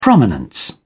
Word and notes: prominence: British English

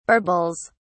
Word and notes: barbells: British English

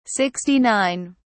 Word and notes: 69: British English

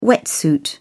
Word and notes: wetsuit: British English

